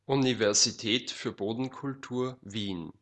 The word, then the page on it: Universität für Bodenkultur Wien: Austrian German; ˌunivɛʁziˈtɛːt fyːɐ̯ ˈboːdn̩kʊlˌtuːɐ̯ viːn